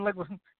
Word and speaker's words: Leguizamo: Oh, John Leguizamo.